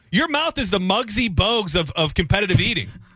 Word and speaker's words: Muggsy Bogues: Yeah, I mean, when Muggsy Bogues, you know, dunked, that was more impressive. – It's Muggsy Bogues. – Damn it, I knew I was going to say that wrong.